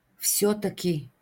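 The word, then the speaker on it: vsjotaki (всё-таки): Vsjotaki, ipak, izgovara se kao jedna reč, akcenat na jo, vsjotaki.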